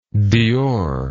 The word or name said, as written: dior